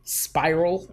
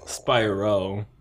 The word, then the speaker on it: Spyro: Spiral instead of Spyro? Like, god damn. You suck. – Bro. I was like, hey, how you say it? Spyro. – Spyro. So Spyro sounds harder because it sounds like he has like spiral horns and shit. – Spyro and Spiral are very different things.